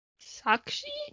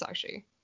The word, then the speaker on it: Sakshi: I think it's Sakshi. Because I listened to it on audio, and that's how they pronounced it.